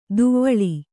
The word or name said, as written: ದವಳ